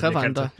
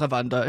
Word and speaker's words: Rwanda: Rwanda, mm, som det Rwanda, ja. Øh... – Landet Rwanda, ikke? – Jo. Flot udtalt, ja.